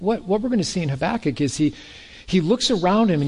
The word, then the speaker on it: Habakkuk: His name is Habakkuk. You kind of have to make a loogie when you say his name. Habakkuk, it's the correct Hebrew way of doing it.